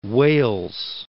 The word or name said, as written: Wales